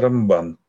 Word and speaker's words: Рамбан: Поэтому Раби Моше бен Маймон называется всегда Рамбам на первый слог ударения, а Раби Моше бен Нахман называется Рамбан на второй слог ударения. Это вот подсказка.